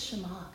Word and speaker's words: Shema: Did I say it right? Shema. I called it the Shema. The Shema last night. He was like, it's Shema.